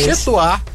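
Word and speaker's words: Chez toi: Chetois, _ em seu lugar. Chetois se escreve Chez, e Toá se escreve TOI.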